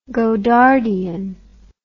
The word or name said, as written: godardian